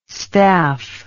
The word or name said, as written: staph